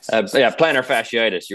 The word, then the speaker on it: plantar fasciitis: I think the last one was about plantar fasciitis. How do you pronounce that? – Plantar fasciitis.